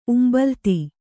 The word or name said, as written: ಉಂಬಲ್ತಿ